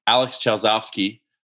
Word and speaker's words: Alex Chausovsky: Um, on today's podcast, we have Alex Chausovsky, uh, and I hope I said that right. – You nailed Alex it. Is a, did I do, did I get it right? – You got it perfect.